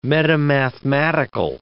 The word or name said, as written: metamathematical